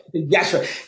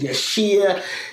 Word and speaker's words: Yashere: So the Yashere, because the I is almost is Yashere, the I is silent. So I dropped the I to help people pronounce it properly, and they still mispronounce it for a long time. I get called Yashir, Yashu, like oh. So I have to make I've got this thing now. I go it's Yashere, think Yashere, and people you know.